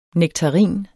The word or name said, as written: nektarin